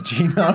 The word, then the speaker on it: Geno: All right, next up on the list, Geno. I think I'm pronouncing that right. – From sure it's Mario. Geno, right? – Yeah, it's Geno, fucking no.